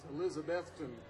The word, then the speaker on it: Elizabethton: And when he was a boy, because he used to go to, it's pronounced Elizabethton, Tennessee.